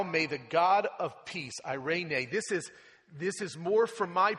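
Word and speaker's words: irene (εἰρήνη): It's interesting that every time I look at the word transliterated into the English, it is tough for me to remember that it's pronounced irene.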